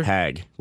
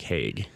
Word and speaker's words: Hague: But yeah, I've heard it's Picard, and we have confirmed it is Nick Hag, not Nick Hague. – Yeah, see, I had like a 10-minute, 15-minute conversation with Nick Hag, and I called him Nicholas Hague the entire time.